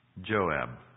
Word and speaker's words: Joab: To the kisses of the enemy, we think of Judas's kiss, or Job's duplicity. Joab, I should pronounce it. Joab, David's captain.